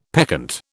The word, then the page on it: peccant: British English